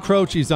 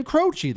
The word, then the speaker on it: Croce: Jim Croce, that's how you say his name. Yes, how do you think you say his name? It's not Croce.